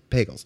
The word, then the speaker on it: Pagels: Now, mentioning the mystic elements of Christianity, I know that you've had interviews with Thomas More and Elaine, I mispronounce her name, is it Pagels or Pagels? – Pagels.